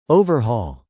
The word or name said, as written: overhaul